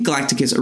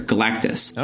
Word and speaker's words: Galactus: Now, the name Galacticus originated from my childhood mispronunciation of Marvel's favorite purple planet eater, Galactus.